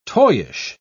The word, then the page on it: toyish: British English